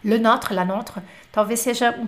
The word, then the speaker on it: Notre: A pronúncia do notre não é mais fechada? Notre. Uh, not, notre Dame, Notre. É, um pouquinho mais fechada.